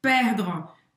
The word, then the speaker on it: perdre: E aqui o E no francês ele não vai ser pronunciado, tá? Ele é realmente ultra discreto. Então, termina a tua pronúncia no R, perdre.